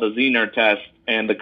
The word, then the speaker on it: Zener: Zener. Right? Am I saying that correctly? – Zener, actually. Zener. I know it's hard to pronounce, but like we went with that name because it was based off of the Zener cards.